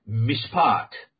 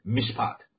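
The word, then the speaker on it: mishpat (מִשְׁפָּט): 那么它的发音呢，公平的发音呢，叫做 mishpat 这个意思啊，这个发音基本上是正确的啊， mishpat 啊，这个发音。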